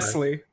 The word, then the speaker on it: Nestlé: I don't know. I was pronouncing it. It's that E. It's yeah, a they Nestle. Have an accent. They have an accent, right? So it's Nestlé, I think.